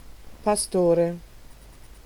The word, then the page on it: pastore: /pa.ˈsto.re/